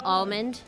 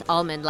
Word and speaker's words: Almond: Michelle Almond. It's Michelle, M-I-C-H-E-L-L-E, and it's Almond like the nut, A-L-M-O-N-D.